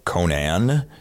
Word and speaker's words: Conan: The dog Conan, pronounced appropriately like the awesome 80s barbarian and not the awesome talk show host from America.